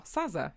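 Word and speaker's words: Sazzerr: And Sazza, I think that's how you pronounce it. It's S-A-Z-Z-E-R-R, Sazza, yeah.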